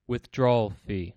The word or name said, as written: withdrawal fee